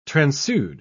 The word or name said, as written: transude